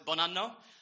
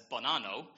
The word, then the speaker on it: Bonanno: So, it's Bonanno, and it's an Italian surname, and it means good year. The thing is that most people, as you just heard, mispronounce it. They pronounce it as Bonanno, which in Italian means good anus.